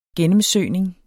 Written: gennemsøgning

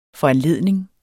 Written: foranledning

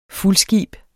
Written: fuldskib

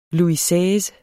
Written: Louis Seize

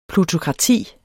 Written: PLUTOKRATIS

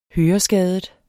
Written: høreskadet

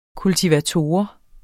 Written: kultivator

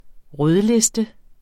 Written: rødliste

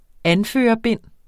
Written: anførerbind

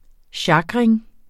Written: sjakring

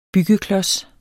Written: byggeklods

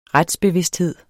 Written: retsbevidsthed

